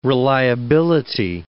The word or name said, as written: reliability